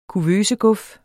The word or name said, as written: kuvøseguf